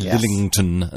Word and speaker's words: Lillington: It's quite hard to pronounce if you don't properly say it and enunciate. You have to say Lillington and then it's okay, but if you just sort of stumble over it, it sounds stumbled.